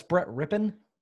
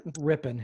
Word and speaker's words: Rypien: Sir, that's Brett Rypien. – Rypien? Is that how you pronounce it? – Rypien. His uncle was Mark Rypien.